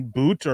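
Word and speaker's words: Boutte: You have uh, Boot or Butte, however you say his name, Butte, the LSU yeah.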